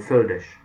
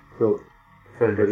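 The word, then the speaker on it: Földes: How do you pronounce the other fellow's name? – Földish. – Földish. This is not F-E-J-E-S. – No, it is F-O, two dots, L-D-E-S.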